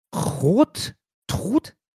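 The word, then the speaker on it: Chrodtrud: Sowie die Tochter Hiltrud gingen aus der ersten Ehe mit, ich weiß ja, wie ich es ausspreche, Rottrud, C-H-R-O-D, Trud.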